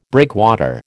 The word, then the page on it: breakwater: American English